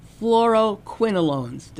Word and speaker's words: fluoroquinolones: He says, and he sums it up by saying, fluoroquinolones, did I say that correctly? – You sounded good.